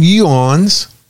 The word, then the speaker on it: yuans: is to sell their oil in yuans. Did I pronounce it Y? Mm-hmm. Y-U-A-N? – Yep.